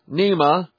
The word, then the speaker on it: NEMA: Officials from the National Environment Management Agency, or NEMA, rhymes with MEMA, have been accused of wrongly giving a license for the LPG filling and storage plant in such a densely populated area.